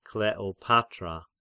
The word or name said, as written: Kleopatra